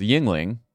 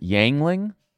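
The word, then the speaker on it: Yuengling: And he called it Yangling. – I mean, that, you'd have to leave the state. It would be like mispronouncing. Yingling or something. It was one of the syllables was very badly pronounced, which is crazy to me because, like, what are you doing, man?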